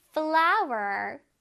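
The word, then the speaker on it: flour: 그 다음에 flower, flower, 밀가루라는 뜻이에요, 그쵸? 쌤이 아까 말했듯이 꽃 flower랑 발음이 동일합니다. 하지만 스펠링이 다른 거 꼭 기억하자, 얘들아.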